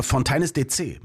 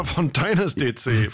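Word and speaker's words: Fontaines D.C.: Und du hast es wirklich falsch ausgesprochen, weil es ist Fontaines DC. – Fontaines DC.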